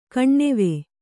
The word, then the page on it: ಕಾಣ್ಣವ: kāṇṇava